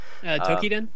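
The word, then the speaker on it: Toukiden: I know Tokiden or Tokiden. I don't even know how you say that. – Tokiden. – Tokiden. Really? Is that how you say it? Tokiden. – I believe that's how it is.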